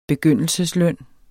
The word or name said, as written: begyndelsesløn